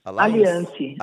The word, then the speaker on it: Alliance: Remax como é que se pronuncia? Alliance? – Alliance. Alliance. Alliance.